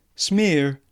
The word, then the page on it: smear: British English